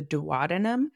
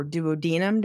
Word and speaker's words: duodenum: which is the duodenum or duodenum, depending on how you say it.